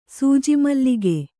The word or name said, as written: ಸೂಜಿಮಲ್ಲಿಗೆ